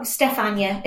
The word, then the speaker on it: Stefania: Oh, Stefania. It's Polish.